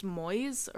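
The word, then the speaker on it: Moïse: Once he became the cult leader, he started going by, I believe it's pronounced Moise. It's like the Hebrew version of Moses, or it's maybe not Hebrew.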